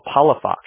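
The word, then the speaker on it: Palafox: Let's do Paula Fox. – For your mother's maiden name? – Yeah. – Okay. And then how do you spell that? – That's P-A-L-A-F-O-X. – Paula Fox. Okay. Oh, Paula Fox, right? – Yeah.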